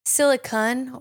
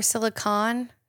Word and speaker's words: silicon: One, silicon or silicon, not an E at the end. Uh-huh. So sometimes people say it's silicone also, but there's no E at the end. So mm-hmm.